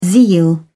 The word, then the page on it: zeal: British English